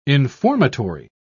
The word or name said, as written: informatory